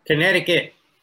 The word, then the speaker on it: Connecticut: En, eh, ¿cómo se pronuncia? – Connecticut. – Connecticut.